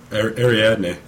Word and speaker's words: Ariadne: Ariadne.